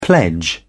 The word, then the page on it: pledge: British English